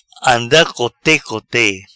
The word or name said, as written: Anda cote cote